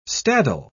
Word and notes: staddle: British English

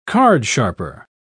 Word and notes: cardsharper: British English

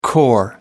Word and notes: corps: American English

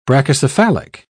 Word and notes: brachycephalic: British English

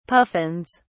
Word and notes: puffins: British English

